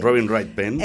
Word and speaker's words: Robin Wright Penn: Robin Wright Penn. Sí. – Robin Wright Penn.